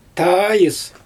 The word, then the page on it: taajɯs: Xyzyl